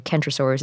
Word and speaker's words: Kentrosaurus: Centrosaurus is with a C, and Kentrosaurus is with a K, and also, as you can tell, they're pronounced differently.